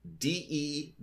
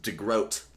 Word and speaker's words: DeGroot: Okay, so here's how it's spelled: DeGroot, like DeGroot. – DeGroot. – Okay, but the secretary immediately corrects him and says it's pronounced DeGroat, like boat. But it's spelled with two O's.